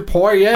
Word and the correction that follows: Poirot: I didn't know that was how it's pronounced. – I don't think that's how it's pronounced. – I thought it was Poirot. – Oh, that's right. That's right. You know what I mean. Poirot.